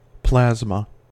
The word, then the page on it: plasma: American English